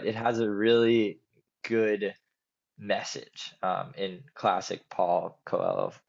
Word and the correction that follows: Paulo Coelho: I'm probably pronouncing that wrong, Paulo Coelho form, really good message.